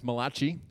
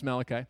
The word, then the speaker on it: Malachi: And the less biblical among us will pronounce it as Malachi, which we're fine with, but it is Malachi. And we call him Mac for short, M-A-C, because I love Macintosh computers, and I love the TV show MacGyver, who doesn't?